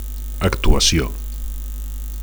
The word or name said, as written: actuacio